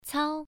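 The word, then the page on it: cao: tone 1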